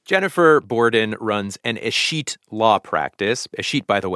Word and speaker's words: escheat: First thing I needed to ask you is, how do you say escheat? – Escheat.